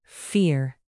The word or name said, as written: fear